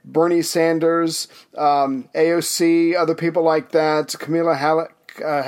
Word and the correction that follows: Kamala: however you say her name, Harris, Kamala, Kamala Harris.